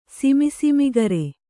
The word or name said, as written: ಸಿಮಿಸಿಮಿಗರೆ